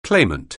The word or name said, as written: claimant